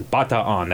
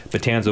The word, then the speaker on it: Bataan: And again, folks, in Tagalog, in the Filipino native tongue, the peninsula there is Bataan. That's how it's properly pronounced. So you'll sometimes get you'll get some Filipinos in their native tongue going, "I wish they would just say it right in the history." But Bataan's okay.